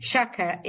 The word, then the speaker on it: Sciacca: So it's Jeanine Sciacca, and I'll spell that. So Jeanine is Jeanine Sciacca, Sciacca.